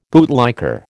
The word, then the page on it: bootlicker: American English